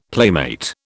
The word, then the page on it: playmate: British English